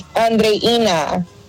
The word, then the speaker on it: Andreina: Okay, now this person's name is spelled like Adriana, but her phonetics are Audrina. Is that right? Are you Audrina? Is that right? – It's Andreina.